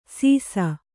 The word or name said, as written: ಸೀಸ